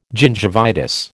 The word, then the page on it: gingivitis: American English